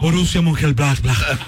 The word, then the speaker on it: Borussia Mönchengladbach: Borussia Mönchengladbach.